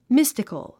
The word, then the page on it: mystical: místik ə l; ミスティカル